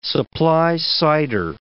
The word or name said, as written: supply-sider